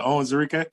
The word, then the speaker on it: Onwuzurike: We got Levi Onwuzurike. Did I say that right? – Yeah, Onwuzurike. – Onwuzurike. I got to put the little twist on it.